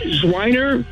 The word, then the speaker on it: Zwiener: Is it Zwiener or Zwiener? – It's Zwiener, but everybody pronounces it as Wiener, too, so you know how that goes.